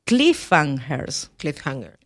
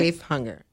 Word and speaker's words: cliffhanger: Por favor, pronúncialo bien. ¿Cómo se? – Cliffhanger. – Ok, cliffhanger. Pero su creatividad no termina en la literatura.